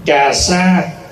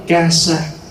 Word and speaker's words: cà sa: Cà sa đọc đúng là ca sa. Chữ ca sa là tiếng Phạn.